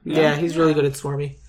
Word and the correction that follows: smarmy: Smarmy. – Smarmy. – Smarmy, that's how you say it.